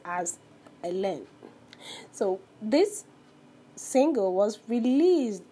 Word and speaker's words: Hélène: And in 1989, Hélène, pronounced as Hélène.